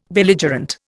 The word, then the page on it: Belligerent: Indian